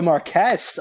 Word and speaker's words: Marquess: Marquis. – The Marquis. – Ah, he is the Marquess of Montrose. – Oh, my goodness. It's just a pronunciation thing.